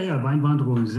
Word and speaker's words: Weinwanderungs: and also the Weinwanderungs. Have I said that right? – Yeah, Weinwanderungs. Yeah